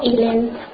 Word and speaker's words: Eilen: Me llamo Ilen. – Ilen. ¿Y cómo se escribe? ¿Me lo puede decir? ¿Cómo se escribe? – Como E-I-L-E-N. Esa.